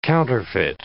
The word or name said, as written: counterfeit